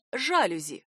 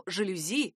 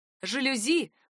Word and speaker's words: жалюзи: Они думают ли составители словарей разрешить вариант «жалюзи»? Уж слишком часто это слово произносят именно так, и на норму «жалюзи» никто не обращает внимания. Ответ был однозначный. «Жалюзи» пока сдавать позиции не собираются и еще повоюют.